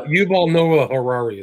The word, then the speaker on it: Yuval Noah Harari: Yeah, I, a lot of Yuval Noah Harari is his name.